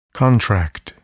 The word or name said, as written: contract